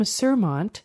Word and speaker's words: Suermondt: Up next is Tim Surmont. And that is spelled S-U-E-R-M-O-N-D-T.